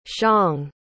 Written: xiang

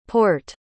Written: porte

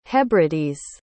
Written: hebrides

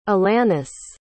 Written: alannis

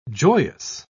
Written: joyous

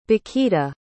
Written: bikita

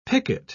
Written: picket

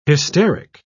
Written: hysteric

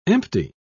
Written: empty

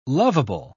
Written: lovable

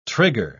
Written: trigger

